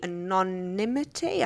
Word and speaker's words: anonymity: And if they want to offer staff the anonymity, I can't even say that word. – No, you said it correctly, which is not bad for a native English speaker.